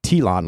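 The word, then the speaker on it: Tlön: it's pronounced T-L-O-N, like Tilon, like Elon Musk, I guess.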